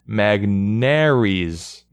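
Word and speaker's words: Magneuris: But the package was headlined by Sandy Alcantara, and I'm not going to know how to pronounce this, Magnuris Sierra? Mm-hmm. Help me, baseball reference. Magnuris Sierra. Let's see. Okay, give me a pronunciation guide. The answer is Magnaries.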